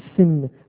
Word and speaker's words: Sin: Son point est inscrit au milieu. Se prononce Shin. Et cette lettre-là se continue de se prononcer Shin tant que le point est inscrit au milieu. Aussitôt que le point est envoyé à gauche, le H disparaît et se prononce Sin, soit mot avec lequel les Anglais ont fait le mal, le péché.